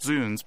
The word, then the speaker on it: zounds: It's pronounced zounds because it's a shortening or a euphemism for God's wounds.